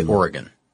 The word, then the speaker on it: Oregon: I think there's a couple of different ways to say it, but anybody from that region will say Oregon.